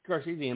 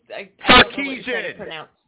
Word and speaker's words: Sarkisian: Sarkisian!